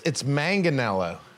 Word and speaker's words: Manganiello: Well, it's Manganiello. Manga. Yeah, I mean, Don Aaron and McKees Rocks, they do the soft G, but up in Mount Lebanon. – Not me, dude. – It's a hard G.